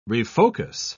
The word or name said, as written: refocus